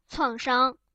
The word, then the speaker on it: 创伤: 创伤。创伤的时候就是名词读成医生，下一个就是语体，大家注意一下口语和书面语的读音。